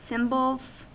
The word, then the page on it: cymbals: American English